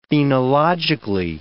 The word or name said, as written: phenologically